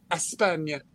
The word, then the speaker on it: España: Ashley, remember to pronounce it España.